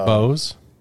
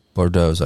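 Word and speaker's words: Bourdeaux's: Bows? – No, Bordeaux's, I thought. That's how you pronounce it. Like the X is silent.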